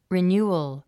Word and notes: renewal: rin j úːəl; リニューアル

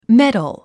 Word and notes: medal: médl; メダゥ

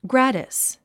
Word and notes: gratis: ɡrǽtis; グラティス

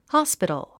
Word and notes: hospital: hɑ’spitl; ホスピトゥ

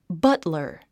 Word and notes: butler: bʌ́tlə r; バトラァ

